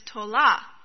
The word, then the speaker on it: tola: Now, the title of the sermon is a Hebrew word, and the Hebrew word is tola. That's how you say it.